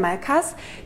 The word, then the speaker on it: Malescasse: Chateau Malescasse schreibt man das. – Ja, würde ich jetzt das S nicht sprechen. Mal ne? – Malcasse? – Es ist ein Malkas, oder? Es ist ein Malkas.